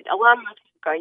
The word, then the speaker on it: Alona Matchenko: Alona Matchenko, yes.